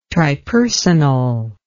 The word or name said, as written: tripersonal